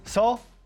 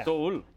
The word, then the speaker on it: Seoul: We say Seoul, Korea. It's actually two syllables in Korean. Seoul. – Yeah. Seoul.